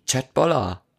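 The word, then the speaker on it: Kjøttboller: Was denn für eine Sprache? – Auf Norwegisch. – Chatboller. Siehste? Genau.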